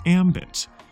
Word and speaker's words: ambit: Today's word rhymes with gambit, but means something entirely different. It's ambit. That's spelled A-M-B-I-T.